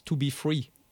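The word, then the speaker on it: 2Be3: But in French, when you pronounce it, that's 2B3.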